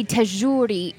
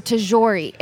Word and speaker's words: Tagouri: It should be Tagouri or Tagouri, if anything. But the thing is, when my dad immigrated here, my dad was also raised in Egypt, and they pronounce their zhes ge.